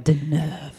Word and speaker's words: Deneuve: But he's good friends with the Albert, the Prince of Monaco, and Catherine Deneuve. – Did I say that right? – Deneuve.